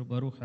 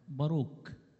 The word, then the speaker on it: باروخ: باروخ عشان نبقى عندنا فكرة، هو خمس إصحاحات، سفر باروخ عبارة عن خمس إصحاحات. وباروخ كلمة عبرية معناها بركة، باروك، يعني الخاء والكاف والشين، الحروف دي بتتبادل في النطق للحرف الواحد.